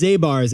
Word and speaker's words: Zabar's: What's the one called, like Zabir's on the Upper West? – It's Zabar's, and we'll have to work on this pronunciation after the pod, Jack.